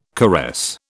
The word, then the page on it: caress: British English